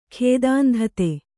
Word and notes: ಖೇದಾಂಧತೆ: Kannada

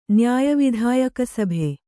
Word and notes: ನ್ಯಾಯವಿಧಾಯಕಸಭೆ: nyāya vidhāyaka sabhe

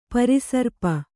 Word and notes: ಪರಿಸರ್ಪ: pari sarpa

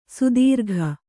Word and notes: ಸಾತಿರೇಕ: sātirēka

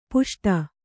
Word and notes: ಪುಷ್ಟ: puṣṭa

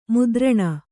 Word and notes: ಮುದ್ರಣ: mudraṇa

